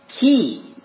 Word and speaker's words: Gaius: And it is often pronounced keys or geese. You see that in the Welsh terminology and some English today, right? Keys. Well, it's Gaius, but it can be keys. It's a different way of pronouncing it.